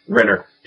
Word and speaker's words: Renner: Renner.